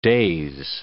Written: daze

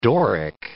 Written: Doric

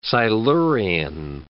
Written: Silurian

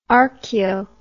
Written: archeo

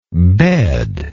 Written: bedless

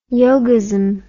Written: yogism

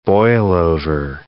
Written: boilover

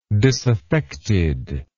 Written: disaffectedness

